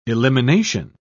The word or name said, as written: elimination